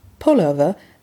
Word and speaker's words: Pullover: Das Wort Pullover wird ebenfalls verwendet, Achtung, auf der ersten Silbe betonen, ist nach meinem Sprachverständnis aber ein wenig förmlicher und in der Umgangssprache wenig geläufig.